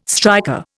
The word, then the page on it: striker: Indian